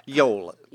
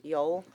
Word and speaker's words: Yule: Joel? How would you say that? – Yol. – Yol? It's because of the J. Always thrown off. – Yeah, it's the way it's pronunciation in the Scandinavian Germanic tradition. It's the J, or sometimes even the Y, has that Y sound to it. It's like the fantasy world that I create stories in is spelled J-E-R-A, but it's Yura.